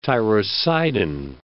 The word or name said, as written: tyrocidine